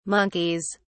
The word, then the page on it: monkeys: British English